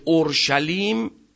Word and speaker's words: اورشلیم: که اورشلیم همون اورسلیمه، اونا چون ابریا سین ندارن شین تلفظ میکنن، یعنی شهری پر از سلامت.